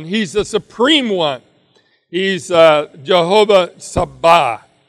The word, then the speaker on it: Jehovah Sabaoth: The emphasis is on the second half. So it's Jehovah Sabaoth.